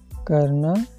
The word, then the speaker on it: karna: Pronunciation of this verb karna, k-a-r-n-a, karna. Second, banana, b-a-n-a-n-a.